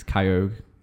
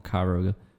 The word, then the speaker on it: Kyogre: No, let's not get into this because it's also Kyogre, not Kyurogue, or whatever people call it. – What? – One from Sapphire, the little dolphin dude, is Kyogre, not Kyurogue.